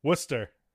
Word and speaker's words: Worcester: Worcester. Say it again. – Worcester. – Yes, I can't get the Boston accent. Worcester. – To everybody not from New England, you want to say it's Worcester, but it's Worcester.